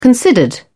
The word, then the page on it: considered: British English